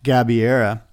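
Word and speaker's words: Gabeira: Well, speaking of Maya, how do you say her name? – Gabiera.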